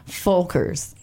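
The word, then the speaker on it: Völkers: L-K-E-R-S. And that is to pronounce F instead of V. So it's Engel and Volkers.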